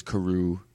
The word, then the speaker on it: Carew: If I'm hearing the musical starring David Hasselhoff in my head correctly, Danvers Carew is how you pronounce it.